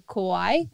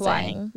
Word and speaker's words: Kauai: We're going to Kauai. Am I saying that right? – Yeah. – Amazing. – Kauai.